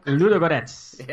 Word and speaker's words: Ludogorets: Ludogorets.